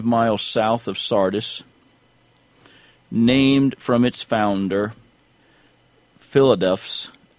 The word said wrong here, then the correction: Philadelphus: and I probably mispronounced that. It's Philadelphus, the king of Pergamos.